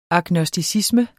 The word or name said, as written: agnosticisme